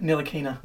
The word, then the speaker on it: Ntilikina: How do you say it? – Ntilikina. I believe.